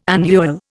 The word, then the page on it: annual: Indian